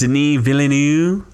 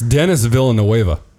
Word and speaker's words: Denis Villeneuve: Important, Denis Villeneuve. – That's stupid. It's Denis Villeneuve.